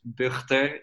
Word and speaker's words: Bugter: My name is a real Dutch name, Lucas Bugter. It's with a G, which is hard to pronounce.